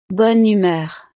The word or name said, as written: bonne humeur